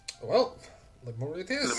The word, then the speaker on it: Lemuria: Oh, Lemuria.